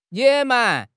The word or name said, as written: 野蛮